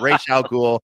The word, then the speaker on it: Ra's al Ghul: That's the right way to pronounce it. It's Ra's al Ghul, not Ra's al Ghul, not Ra's al Ghul. No. It's Ra's al Ghul. It's a Hebrew pronunciation with an Arabic, naming convention.